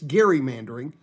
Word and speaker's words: gerrymandering: And by the way, you can say it gerrymandering. Ronald Reagan knew how to say it. It's Governor Gary. It was who it was named after, a governor, Governor Gary. It's gerrymandering.